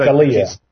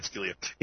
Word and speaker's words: Scalia: Let's hear what Justice Antonio Scalia, I hope I'm saying his name right. – Scalia. – Scalia.